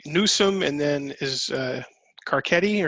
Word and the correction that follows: Garcetti: or Garcetti, you pronounce it, Garcetti. – Yeah, Garcetti, yeah.